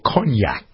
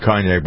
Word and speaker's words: cognac: You can tell I know a lot about cognac by listening to me try to figure out how to pronounce it. It's not cognac, cognac en français, right?